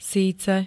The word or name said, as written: síce